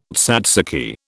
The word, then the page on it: tzatziki: British English